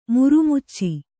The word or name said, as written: ಮರೆಮಾಚು